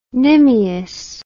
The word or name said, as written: nimious